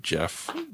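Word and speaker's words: Geoff: Jeff, but it's spelled G-E-O-F. – Oh, yeah. – Goff.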